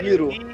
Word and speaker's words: Iroh: Hiro. É, se escreve assim, ó. É, Hiro. – I-R-O-H. – Hiro.